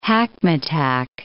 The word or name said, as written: hackmatack